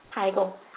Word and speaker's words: Heigl: Heigl, how do you pronounce it? – Heigl. – I was right.